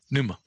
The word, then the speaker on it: pneuma: speak pneuma. Of the Holy Spirit. – Pneuma.